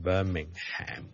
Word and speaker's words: Birmingham: 如果你美国人咧，可能会读埋个 Ham 出嚟。 Birmingham 咧就可能会读成 Birmingham 咁样。咁但系如果英国咧，大部分人咧个 HM 都系唔同嘅。今日就讲到呢度啦，拜拜。